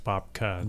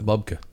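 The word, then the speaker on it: Bobke: But to reiterate what you said, so Bob Roll had his first book called Bob Key. – Yeah. – It's actually should be pronounced Bobka. – Bobka.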